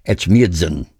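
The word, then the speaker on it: Etchmiadzin: This was originally an intermezzo for his 1946 opera, I can't even pronounce it's an Armenian word. It's like Echmiadzin. That's pretty close.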